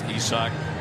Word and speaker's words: Isak: Isak Paredes, spelled like Isaac Collins, but pronounced Isak.